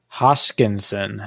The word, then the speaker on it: Hoskinson: Hoskinson, I think that's the correct pronunciation. – Close enough. Yeah, close.